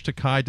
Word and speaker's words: Takei: It's and, Takei. You know, it rhymes with sorry.